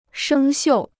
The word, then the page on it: 生锈: shēng xiù